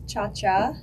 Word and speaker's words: Ciaccia: So technically, yes, it's Ciaccia in Italian, but I was misled my almost entire life that we thought it was Ciaccia.